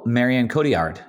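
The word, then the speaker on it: Marion Cotillard: Oh, Marianne Cotillard.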